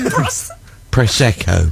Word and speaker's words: Prosecco: Prosecco.